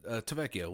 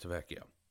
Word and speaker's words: Tavecchio: Now, they definitely learned their lesson from last season where they let Giorgio DeVecchio, if I'm pronouncing that name right. It's been a little while since, you know, we've heard that name, and it was a little hard to pronounce that, but Tavecchio. Let's just say that, Tavecchio.